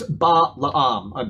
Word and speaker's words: Balaam: I know, well, however you pronounce it. But his name is Balaam, B-A-L-A-A-M, okay? – It's like...